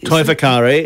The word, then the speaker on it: Toi Whakaari: You'll have to correct my pronunciation. Toi Whakaari? – Toi Whakaari is the New Zealand drama school.